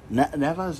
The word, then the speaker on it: Nevaeh: Killian King with Taylor Wilde defeated Nevase? Neva, is that who it is? It's Neva. Neva, okay, well, here's how it's spelled. N-E-V-A-E-H.